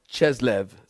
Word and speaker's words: Chislev: Verse 22 brings us to the Feast of Dedication, which is the 25th of Chesluv on the Jewish calendar, or Chislev, some people pronounce it C H I S L E V, the 25th of Chislev, or December.